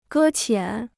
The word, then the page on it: 搁浅: gē qiǎn